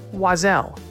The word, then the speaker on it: Oiselle: As I quickly learned, it's pronounced Wazelle, and it's spelled O-I-S-E-L-L-E. It's French for bird.